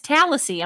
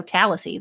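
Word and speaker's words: Tallassee: No, it's Tallahassee. I'm going to go with because it's T-A-L-L-A-S-S-E-E. So Tallahassee, that's what I'm going with. – Okay, sounds good. – Maybe it's Tallahassee.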